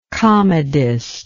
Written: comedist

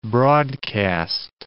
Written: broadcast

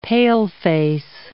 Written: paleface

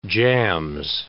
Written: Jams

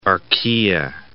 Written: archaea

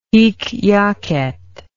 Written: hic jacet

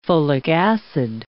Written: folic acid

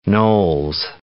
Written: Knowles

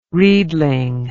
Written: reedling

